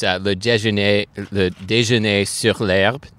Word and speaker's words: le déjeuner sur l'herbe: It's le déjeuner sur l'herbe.